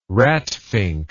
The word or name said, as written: ratfink